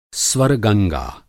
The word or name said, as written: स्वर्गंगा